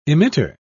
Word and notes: emitter: British English

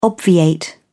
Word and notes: obviate: British English

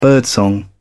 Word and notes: birdsong: British English

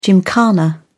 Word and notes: gymkhana: British English

